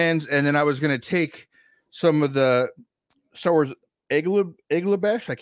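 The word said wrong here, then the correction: Aurebesh: Arabesh, yeah, there you go.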